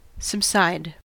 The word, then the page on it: subside: American English